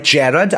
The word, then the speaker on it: Gerard: That's right, Gerard. I'm saying it in the British pronunciation because we are where the words came from.